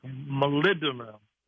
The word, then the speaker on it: molybdenum: And I noticed one of the nutrients was molybdenum.